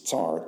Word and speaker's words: tsar: I remind you, if you weren't in those studies earlier, the word narrow in Hebrew is tsar, T-S-A-R, we would spell it.